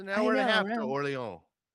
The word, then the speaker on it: Orléans: You live close to Orléans. Is that how you say it? Orléans? – Yeah, I mean, sure.